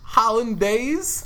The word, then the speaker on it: hollandaise: Hollandaise? – Okay, that's right.